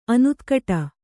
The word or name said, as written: ಅನುತ್ಕಟ